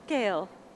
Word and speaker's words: Gail: R-O-B. – And your name? – Gail. – Gail, can you spell that? – G-A-I-L.